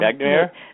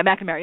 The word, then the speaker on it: McNamara: Manera, Mac McNamara. Help me out. McNamara. How do you say his name? – Um, McNamara. – Yeah, McNamara. Yeah, exactly.